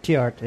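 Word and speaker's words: Theart: and ZP Tiert. Did I say it right? I'm trying to get it right. Is it Tiert? Did I say it right? Tiert. – Tiert, yeah.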